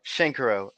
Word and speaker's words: Shenkarow: Shenkarow.